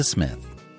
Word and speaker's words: Psmith: And now, leave it to Smith. The P is Silent, Part 4 of 10, by P.G.